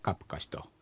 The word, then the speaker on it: カプカシ: はい、 1 番がですね、カプカシ、これは前回も出てきたやつですかね、私が歩くですね、空が、言うの音が脱落しちゃって、猿方言ではカプカシと、私歩く、クワプカシがカプカシと言っちゃうと。